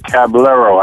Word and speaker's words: Caballero: This is your host of Loving That Sports Talk, with my guest, Rick Caballero.